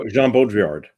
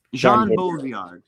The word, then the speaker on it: Jean Baudrillard: Jean Baudrillard. Is that how you say that? – Oh, Jean Baudrillard. – Jean Baudrillard. – Yep.